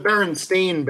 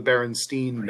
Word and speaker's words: Berenstain: Oh, they're the Berenstain Bears. They're spelled like S-T-A-I-N. But most people think they're the Berenstein Bears.